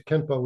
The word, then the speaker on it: Kenpo: So Kenpo, in Japanese, Xuan Fa is pronounced as Kenpo, which means law of the fist.